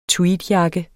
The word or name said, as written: tweedjakke